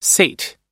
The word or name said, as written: sate